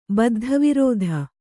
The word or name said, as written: ಬದ್ಧವಿರೋಧಿ